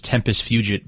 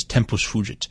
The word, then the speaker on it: Tempus Fugit: One of the tracks off of Drama is this one called, well, the American pronunciation is Tempus Fugit, but I think the proper pronunciation is Tempus Fugit.